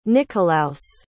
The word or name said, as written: nikolaus